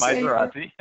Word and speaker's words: Misuradze: Craig, I didn't say your name right. – That's okay. You're not the first one, Steve. It's Misuradze.